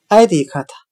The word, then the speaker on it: addict: 我们用谐音法来记这个单词 addict，它的发音很像汉语的哀地克他，悲哀的哀，弟弟的弟，嗑药的嗑。